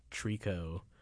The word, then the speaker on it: tricot: I'm thinking there's this band called Trico. They're a Japanese band, but it's spelled T-R-I-C-O-T. And then the album's name is called And, like A-N-D, like in English.